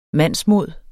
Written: MANDSMODS

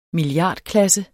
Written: milliardklasse

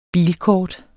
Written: bilkort

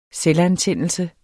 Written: selvantændelse

